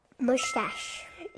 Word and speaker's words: mustache: Mustache. – Yes, mustache. 여기 쉿 소리가 나요.